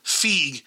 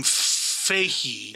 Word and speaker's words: Feig: And I'm like, why does he pronounce his name Feig? And why does he pronounce his name Feige? – Is it Booger? – No, hold on. So Kevin Feige, let me see.